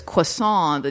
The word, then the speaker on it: croissant: And then, the one with the yellow hair, she says, Beth Barrett's character goes, oh, it's croissant, the T is silent.